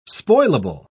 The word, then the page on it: spoilable: British English